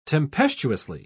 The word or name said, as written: tempestuously